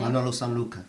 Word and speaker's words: Sanlúcar: Manolo Sanlúcar.